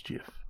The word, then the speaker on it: GIF: It is an animated GIF, and I'm going to pronounce it GIF because that's the right way to pronounce it. – It's not GIF? – No, it's GIF. It says so in the documentation.